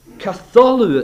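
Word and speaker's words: Katholu (καθόλου): Katholu, it's pronounced in the Greek.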